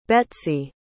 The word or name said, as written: Betsey